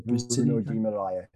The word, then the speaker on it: Bruno Guimarães: Is it Bruno Guimarães? Is that right? – The Bruno Guimarães, yeah.